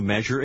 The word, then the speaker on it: measure: Как и сочетание T-U-R-E, S-U-R-E также произносится не так, как читается. Прочитайте номер 8 после диктора. – Did you measure it?